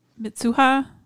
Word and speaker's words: Mitsuha: And then Taki's like, I stopped waking up as Mizuha, or how do you say her name? I can't remember. – Mitsuha. – I think you said it right.